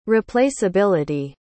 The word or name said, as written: replaceability